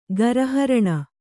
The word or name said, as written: ಗರಹರಣ